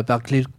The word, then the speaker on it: Berklee: Tu es effectivement passé par Boston dans la Berklee College of Music, mm-hmm. si je prononce correctement. – Très bien.